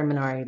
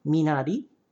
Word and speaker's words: Minari: I also think it's really funny how people are running around saying Minari, which is the, you know, Korean pronunciation of that word, sort of like how people say Barcelona.